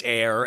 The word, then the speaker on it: Aire: It was Wolf, now it's Air, and it's spelled A-I-R-E.